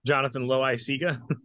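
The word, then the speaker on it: Jonathan Loáisiga: Jonathan Loaisiga.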